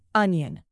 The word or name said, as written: onion